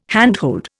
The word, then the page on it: handhold: Indian